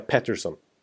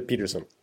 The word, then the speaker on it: Pettersson: Men efter när Colorado-matcherna gjorde sina fem poäng då, då liksom Ja, då slog TSN fast nu att nu, nu ska vi säga Patterson, inte Peterson. Så nu är det utrett. Ja. Eh, de blev nerringda och nermejlade och sådär att han faktiskt ska uttalas Elias Pettersson.